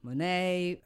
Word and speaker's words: Monet: Monet, Manet, Van Gogh.